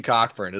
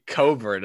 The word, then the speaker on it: Cockburn: I'm not just saying like in the paint against, by the way, it's not Kofi Cockburn as we had thought. It's Kofi Coburn, as they pronounce the entire,